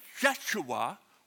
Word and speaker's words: Yeshua: It's pronounced as Yeshua, which is the name Joshua.